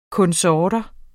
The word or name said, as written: konsorter